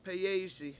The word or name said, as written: peyEEysi